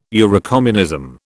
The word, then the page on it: Eurocommunism: British English